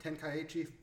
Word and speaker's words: Tenkaichi: Then we're going to hop over to a fighting game, the first fighting game of this season, Dragon Ball Z Tenkaichi 3.